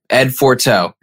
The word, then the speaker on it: Ed Forteau: And today we have Ed Forteau.